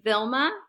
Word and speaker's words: Vilma: Spells my name like Velma or like Zelma, Thelma. I've heard like so many variations of my name. So it's Vilma.